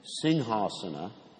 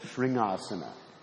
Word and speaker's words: Singhasana: He serves the Lord as his Singhasana. Singhasana means lion throne. Some of you mispronounce that word. You say Sringasana. I've heard devotees do that.